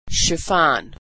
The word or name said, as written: chiffon